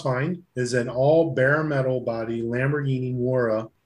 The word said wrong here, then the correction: Miura: Moira? – Mira. – I don't know how to pronounce these words. – Moira. – Moira. – Moira. Moira. – It's a Moira. It's a Lamborghini Moira. The Miura. – Miura. I'm not Italian.